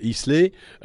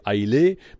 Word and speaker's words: Islay: Alors, euh, souvent, on, l'appelle euh, Islay euh, ou encore Aïlé, mais nous, autochtones, on la prononce Ayla. Ayla se trouve à l'ouest de l'Écosse,